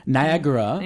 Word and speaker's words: Niagara: 본토 발음 기억이 나죠? – Yeah. – Niagara.